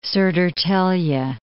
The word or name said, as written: Sodertalje